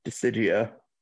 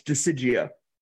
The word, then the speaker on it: Dissidia: He said it wrong. It's Dissidia. – Yeah, it's Dissidia. – I hate that. – Yeah. Oh no, it's absolutely awful. But it's the final it's Final Fantasy naming conventions.